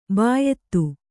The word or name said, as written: ಬಿಯದಿ